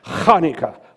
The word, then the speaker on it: Hanukkah: Now, some say Hanukkah, but I want you to pronounce it with me. Hanukkah, Hanukkah. Start deep in your throat.